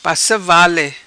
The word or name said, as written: Passa vale